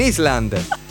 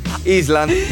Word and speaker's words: island: He was living on an island. Ecco, island non si dice, si dice island.